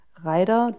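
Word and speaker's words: Raider: Wusstest du, also der hieß ja damals Ryder und Ryder nicht Ryder englisch ausgesprochen, sondern wirklich Reider, deutsch ausgesprochen, weil der dann zum ersten Mal 1967 ähm, ja, im Vereinigten Königreich eingeführt wurde.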